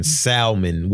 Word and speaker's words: salmon: It's a lot of people that's going to stop listening to our pod if y'all niggas keep pronouncing salmon with an L.